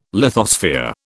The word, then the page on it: lithosphere: British English